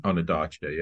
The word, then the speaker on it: Ondaatje: I'm reading Walter Murch and Michael Ondaatje. Is that how you pronounce his name? – Ondaatje, yeah.